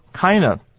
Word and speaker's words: kind of: kind of 念快的时候呢，那个 d 也不能 find，因为它在 n 的这个字母后面。我们念作 kind of kind of，然后后面的那个 of 呢，f 是不 find 的，所以我们等于只有念 k i n o 这四个声音，念作 kind of。